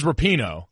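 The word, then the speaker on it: Rapinoe: Did you say Rapone and then say why do you always say it wrong? It is Rapino.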